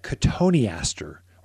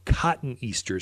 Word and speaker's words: cotoneaster: One I like, especially if you're in the forested areas where you've got a lot of deer, even elk, you'll look at cotoneaster or cotton easter is how you spell it.